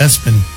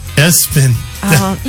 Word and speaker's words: ESPN: A-C-D-M-E-L-O-P. – How about this one? ESPN, but it's pronounced Espen. Espen.